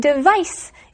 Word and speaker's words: device: That's right, but there is a little bit of a difference in the pronunciation of those two words. Devise is the verb and device is the noun. – And actually, device, the noun, is spelled with a C, D-E-V-I-C-E.